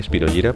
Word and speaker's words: Spirogyra: Spirogyra se dice así, Spirogyra, porque es un nombre en latín, aunque es una banda fantástica de jazz, de las legendarias, de Jay Bekenstein, el saxofonista.